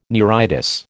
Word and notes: neuritis: American English

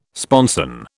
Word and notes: sponson: British English

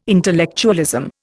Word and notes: intellectualism: Indian

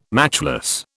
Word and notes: matchless: British English

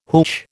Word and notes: hootch: American English

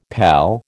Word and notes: pal: American English

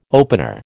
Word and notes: opener: American English

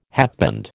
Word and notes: hatband: American English

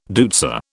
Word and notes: deutzia: British English

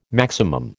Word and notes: maximum: American English

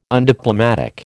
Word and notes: undiplomatic: American English

